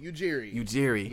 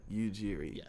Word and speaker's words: Ujiri: Case in point, Masai Ujiri, how do you say his name? – Ujiri. Yes. – Got it. It's like Injera, Ethiopian, but like Ujiri.